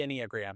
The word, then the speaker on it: Enneagram: The Enneagram.